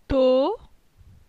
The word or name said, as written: To